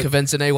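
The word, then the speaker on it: Quvenzhané: But Kevenson A.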